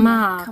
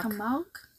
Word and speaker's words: Camargue: Wie wird das ausgesprochen? Camargue. Camargue? Ja. oh Gott, ich bin wirklich durch für heute mit dem Französischen.